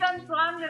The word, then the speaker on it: Antoine: Pero se dice Antoine de toda la vida.